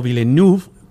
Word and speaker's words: Villeneuve: ajá, el director es Denis Villeneuve o Villeneuve, pronunciado en francés, que es el director de pues.